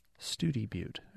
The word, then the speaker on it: Study Butte: And Terry says, I just returned from Big Bend and spent a night in a town, a two-word town, S-T-U-D-Y-B-U-T-T-E. You might think it's Study Butte, but it's actually pronounced Studi Butte out there.